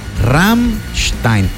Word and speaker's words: Rammstein: Rammstein.